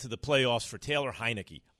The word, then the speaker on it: Heinicke: They probably don't want to upset the apple cart when they got a young man like Tyler Heinicke. Did I say it right? – Yeah. Taylor. Yes. Yeah. – Taylor Heinicke. I always want to say Heinicke.